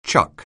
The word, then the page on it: chuck: anglais